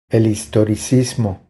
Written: El historicismo

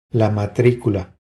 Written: La matricula

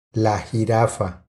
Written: La jirafa

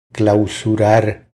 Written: Clausurar

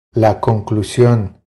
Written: La conclusion

